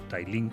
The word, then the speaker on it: Tallinn: Y es eh, Tallin, Tallinn o Tailín, como lo pronuncian allí, que es la capital de Estonia.